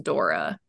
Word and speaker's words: Dore: and it's it's spelled D O R E, but it's pronounced Dora.